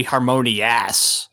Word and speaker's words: Harmonious: Harmonious, with the emphasis on the us, because they couldn't just call it Fantasmic 2. – If it sucks, it's going to be Harmonious.